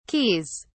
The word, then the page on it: keys: British English